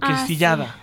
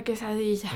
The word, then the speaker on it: quesadilla: dime si me equivoco el nombre, la pronunciación, ¿es quesadilla o quesillada? – Sí, la quesadilla.